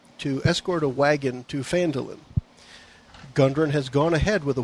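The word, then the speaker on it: Phandalin: By the way, as far as pronunciation goes, the adventure book here actually points out the correct pronunciation, and it is Fandolin. So fan is the emphasis is on fan, but it is fan, and then it's Duhlin is the three syllables.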